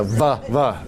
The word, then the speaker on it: Worms: Yeah, we've got to pronounce it in the German, the worms, the va. Yeah. Yeah, worms, not the diet of worms.